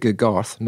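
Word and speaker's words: GGGarth: Produced by, now I believe this is actually pronounced Garth, not G.G.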